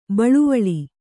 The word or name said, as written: ಬಳ್ವಳ